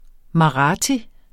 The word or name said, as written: marathi